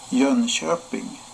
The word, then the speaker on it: Jönköping: Jönköping.